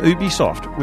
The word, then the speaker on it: Ubisoft: Now, Brian, you were saying before that Ubisoft, which is one of the big...